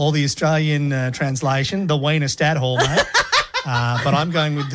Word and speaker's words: Wiener Stadthalle: It's at the Wiener Stadthalle.